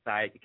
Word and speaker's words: Said: Or just find me on Facebook, Said Kasidibinga.